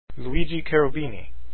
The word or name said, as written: cherubini